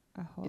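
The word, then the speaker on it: Oholiab: It's because it's, Aholyov. It's two kamats, like, in a row.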